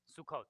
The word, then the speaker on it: Sukkot: What's my good pronunciation? Sukkot? Sukkot. I never remember what's the long and the short O.